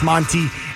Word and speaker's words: Mwznty: Again, that's spelled M-W-Z-N-T-Y, like Muse T. That's Monty.